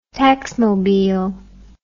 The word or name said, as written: taxmobile